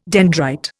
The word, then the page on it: dendrite: Indian